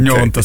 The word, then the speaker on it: Gnonto: Questo è Gnonto, credo. Okay. Gnonto, sì, non il GN argentino. – Però è bello, Gnonto.